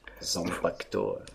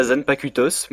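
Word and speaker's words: Zanpakutō: Eh bien, ce sont tout simplement des Zanpakuto. Tout simplement. – Zanpakuto. – Zanpakuto. – Ah là, moi j'aurais dit ça Zanpakutos, mais.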